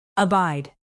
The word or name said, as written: abide